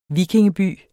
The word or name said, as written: vikingeby